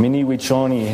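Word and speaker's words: Mni Wiconi: Mini Wichoni is how they say it.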